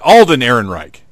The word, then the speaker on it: Alden Ehrenreich: Aiden Ehrenreich? – Is it Alden? – Alden. Alden Ehrenreich.